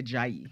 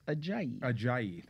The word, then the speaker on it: Ajayi: Jay Ajayi. – Thank you, because I'm afraid to say it. – Ajayi.